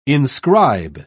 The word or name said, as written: inscribe